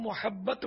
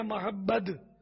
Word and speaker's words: محبت: آخر میں تا ہے، تا تے، اور میم پر ہے زبر. عوام محبت بولتے ہیں، محبت عربی، اصل یہ لفظ عربی ہے، عربی میں یہ زبر آئے گا، محبت آخر میں تے. انہوں نے محبت یا محبد تلفظ پوچھا تو آخر میں دال نہیں ہے، محبت.